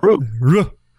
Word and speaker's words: Rue: Well, you just say it like a Cajun would say it. Rue.